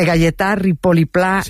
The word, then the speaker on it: Gaietà Ripoll i Pla: a ver si lo digo bien, de Gaietà Ripoll i Pla, un mestre y un pensador que tenía la seua escola a l'Horta de Russafa. – Perfecto. – ¿Lo he dicho bien? – Sí, y se ha entendido perfectamente.